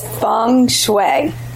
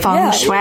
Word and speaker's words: feng shui: feng shui. Is that how you say it, Katie? – It's feng shui. – Feng shui. – Yeah. – Okay, I love it. Like, gung-ho. Okay, feng shui.